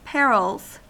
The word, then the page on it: perils: American English